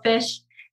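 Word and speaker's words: Fisch: So, yeah, my last name is spelled fish. It's F-I-S-C-H.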